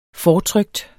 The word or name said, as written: fortrykt